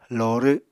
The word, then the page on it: lorw: Apatani